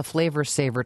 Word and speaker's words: Flavr Savr: It was, tell us about the Flavor Saver tomato. – Well, the Flavor Saver, which for those of you who are language fans, it's spelled F-L-A-V-R-S-A-V-R,